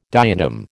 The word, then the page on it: diatom: American English